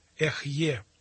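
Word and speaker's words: Эхье (אהיה): На еврейском языке это имя произносится как Эхье.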